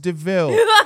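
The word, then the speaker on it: De Vil: It's spelled like Devil, but pronounced DeVille.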